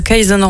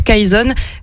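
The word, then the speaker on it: Kayson: Et l'année prochaine, Kayzone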